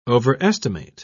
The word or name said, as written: overestimate